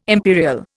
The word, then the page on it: imperial: Indian